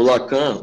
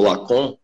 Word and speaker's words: Lacan: Porque o Lacan, ou Lacan, né, como é a pronúncia em francês,